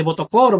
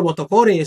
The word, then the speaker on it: Botocore: de Botocore o Botocore, según como lo quieras eh, pronunciar,